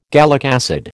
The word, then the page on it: gallic acid: American English